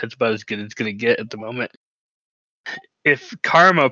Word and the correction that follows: Kamara: plays, not Karma, I can't pronounce his name. – Kamara. – Yeah, him. Thank you. Kamara plays.